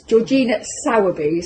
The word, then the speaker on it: Georgina Sowerby: Hello, this is Love Your Creativity, and today I have got, I'm very excited about this, Georgina Sowerby, is that correct? – That's right.